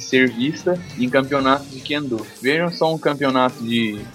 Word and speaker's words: Kendo: Aqui no caso tem, aqui no caso é Kendo a pronúncia.